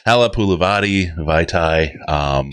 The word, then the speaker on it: Halapulivati Vaitai: Next is what I feel is a pretty good upside type of signing, Halapulivati Vaitai.